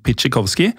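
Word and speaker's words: Pietrzykowski: Og bokserens fulle navn var Tadeusz, og så skrives det Pieter Sykowski. Jeg har fått høre at det uttales noe sånt som Pitschikowski.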